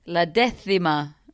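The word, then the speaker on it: La Décima: How do you say it? – La Decima. La Decima, yes.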